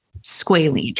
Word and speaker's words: squalene: Okay, first, before we get there, you may notice that Jeffrey pronounced squalene and squalene differently. – Yes. – Well, our bodies produce a lipid called squalene with an E to moisturize and rejuvenate our skin. By the time you're 20s, 30s, and 40s like me, we're toast. It begins to decrease in our skin and it looks drier and damaged. Okay. That is squalene.